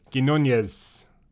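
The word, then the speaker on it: Quiñones: Quinones. – It's not Nyes. There's no Nye at the end.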